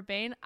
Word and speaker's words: Behn: Because Behn is spelled B-E-H-N. I think that's Behn.